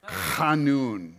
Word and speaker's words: Hanun: Did you like my pronunciation there? That's super Hebrew. Hanun. You want to try with me? They get that little thing in the back of the throat.